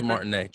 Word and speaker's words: Martinet: And then right there, it says special forward by legendary voice actor Charles Marinette." Did I pronounce Charles Martinet. Charles Martinet. Martinet. Martinet.